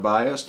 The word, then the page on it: biased: American English